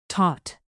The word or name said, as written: taut